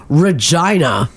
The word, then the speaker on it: Regina: and then Regina. – Regina. – Regina coming up on there. – Mm-hmm. – So, I don't know. That doesn't seem right to me. – It's because their town rhymes with fun.